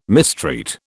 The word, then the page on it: mistreat: British English